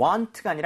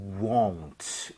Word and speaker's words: won't: 자, 그리고 won't 할 때는 입모양을 많이 모아야 돼요. 그래서 you want가 아니라 you won't. 이렇게 꼭 발음하시길 바라겠습니다.